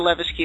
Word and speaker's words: Levesque: Well, if this is a united heel front that's going to go up against the evil boss, Dr. Levesque, and oh, by the way, thank you, Sean, for actually telling me that I've been saying it right all along.